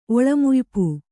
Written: ಒಳಮುಯ್ಪು

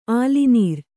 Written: ಆಲಿನೀರ್

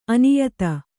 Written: ಅನ್ಯಥಾ

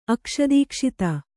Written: ಅಕ್ಷದೀಕ್ಷಿತ

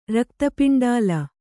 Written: ರಕ್ತಪಿಂಡಾಲ

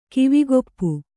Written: ಕಿವಿಗೊಪ್ಪು

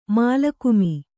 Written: ಮಾಲಕುಮಿ

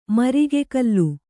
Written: ಮರಿಗೆಕಲ್ಲು

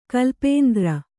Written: ಕಲ್ಪಾಂತರ

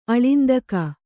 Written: ಆಳಿಂದಕಿ

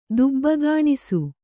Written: ದುಬ್ಬಗಾಣಿಸು

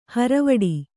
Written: ಹಾರಿವಡೆ

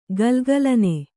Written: ಘಲಘಲನೆ

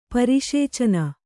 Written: ಪರಿಶೇಚನ

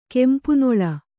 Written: ಕೆಂಪುನೆಲ್ಲು

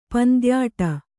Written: ಪಂದ್ಯಾಟ

